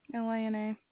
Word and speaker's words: Lina: L-I-N? – Lina. – L-I-N-A.